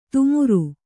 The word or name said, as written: ತುಮುರು